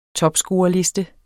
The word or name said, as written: topscorerliste